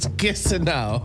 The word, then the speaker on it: kisinow: Our Cree word of the day is kissinow. – Kissinow. – Kissinow. – Kissinow. – Kissinow, like it's cold. – Kissinow. – Kissinow. – Kissinow. – Yeah. Kissinow. – Pretty much sounds exactly how it's spelled. – K-I-S-I-N-O-W.